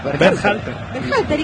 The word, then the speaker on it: Berhalter: ¿Cómo es el apellido? – ¿Cómo? – Dígalo usted. – Berhalter. – ¿Y qué dije yo? – No, impecable.